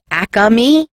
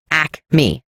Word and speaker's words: Acme: People from Philly call it Acme, but it's not. It's Acme. Two syllables, no extra A.